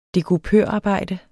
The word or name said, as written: dekupørarbejde